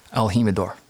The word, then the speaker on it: El Jimador: It's, how do you say the name of the bottle or the name of the tequila? – El Jimador. – Exactly, right? So anyhow,